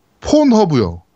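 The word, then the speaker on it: Pornhub: 폰허브라 그러는데 뭐 발음 제대로 해주세요. 다시요. 폰허브요.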